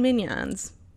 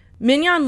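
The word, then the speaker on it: minyan: Anyway, I had no idea Jews did exorcisms, but I can give you a little background on minions. Well, so we called them minions in the episode. Minion literally means number and is pronounced minion. Okay, there you go. Minion.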